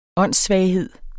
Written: åndssvaghed

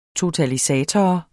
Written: totalisator

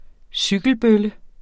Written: cykelbølle

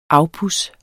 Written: afpuds

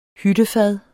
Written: hyttefad

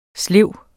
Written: slev

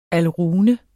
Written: alrune

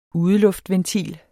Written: udeluftventil

